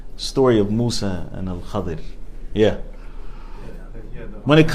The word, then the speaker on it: Al-Khadir: The story of Musa and Al-Khadir. Al-Khadir. Al-Khadir. Now. There's two different ways to pronounce it. Khidr. Al-Khadir is more correct.